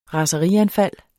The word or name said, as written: raserianfald